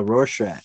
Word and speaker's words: Rorschach: Oh yeah, Rorschach. – Rorschach.